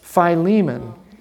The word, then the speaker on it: Philemon: Philemon.